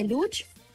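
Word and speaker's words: Łódź: muy moderno el estadio de Lucz, que así se pronuncia en polaco, ¿no? Pero yo quiero, me siento más segura cuando digo Lutz, porque así que acá nos encontramos, les envío un fuerte abrazo acá en territorio europeo, específicamente en Polonia.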